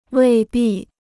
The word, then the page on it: 未必: wèi bì